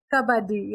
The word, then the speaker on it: Kabaddi: Kabaddi? – Kabaddi.